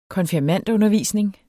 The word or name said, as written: konfirmandundervisning